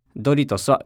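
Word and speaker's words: Doritos: ちなみに M&M's の発音は M&M's、ドリトスはドリトスです。使う頻度は低いと思いますが、いい発音練習になるので一緒にやってみましょう。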